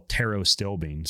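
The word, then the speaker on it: pterostilbene: Well, taro-stilbene, spelled like pterodactyl, P-T-E-R-O, stilbene, that's like 100 times more powerful than resveratrol.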